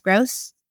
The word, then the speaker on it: Grose: On Twitter, I'm at Jess Grose, and my last name is spelled G-R-O-S-E.